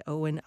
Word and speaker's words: Eowyn: It is called The Snow Child by Owen Ivey. And Owen is spelled E-O-W-Y-N.